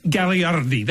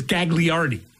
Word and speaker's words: Gagliardi: Yeah, like Maria's last, her maiden name is Gagliardi. That's how you pronounce it in Italian. But everybody says Gagliardi.